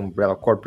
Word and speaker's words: Umbrella Corps: É, é um jogo mais rico, tem o, negócio do Umbrella Corp, que, que é Umbrella Corps, que eu falo Umbrella Corp porque eu, é, é a pronúncia correta, o negócio dele é que pouca gente jogou multiplayer de verdade, assim, partida fechada, porque o jogo sofria com falta de jogador.